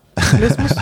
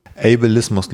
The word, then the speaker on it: Ableismus: Also Able. wie Able, so able. I'm, I'm able to do something und dann Ismus einfach. Ableismus. – Aber willst du Englisch ausgesprochen? Ableismus? – Ach so. Ach so, du sagst fast einfach nur Deutsch. – Ja.